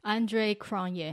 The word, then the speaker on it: Andre Cronje: AC 啊，Andrew Crouch 啊，Andrew Crouch，这个英文应该怎么念呀、 Andre Cronje。